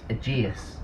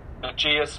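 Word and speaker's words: Ageas: I want to say Aegeus, it's Aegeus. Get your pronunciation. – Aegeus, it's Aegeus, presumably like the Aegean Sea. – Just like the Aegean Sea, yes.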